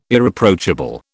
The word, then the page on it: Irreproachable: British English